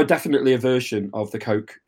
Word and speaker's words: Koch: So they are the Koch brothers. – They are. Then again, I'm going to have another question about how you pronounce that. Is it the Koch brothers? – Definitely Koch brothers.